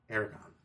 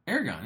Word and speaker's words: Aragorn: You said Aragon? – No, I said Aragon. – Aragorn, yeah. – But not with the A. I said with the A, R. – Oh, okay. – Like Aragorn.